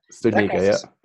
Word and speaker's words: Studnicka: Like, there is a pool of talent, and we haven't even talked about Jack Studnicka, how do you say his last name, Studnicka? – Studnicka, yeah.